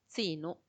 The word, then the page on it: tsinu: Macuiltianguis